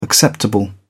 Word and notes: acceptable: British English

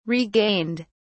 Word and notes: regained: British English

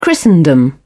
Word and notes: christendom: British English

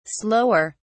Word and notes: slower: British English

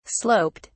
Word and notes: sloped: British English

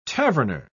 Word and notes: taverner: British English

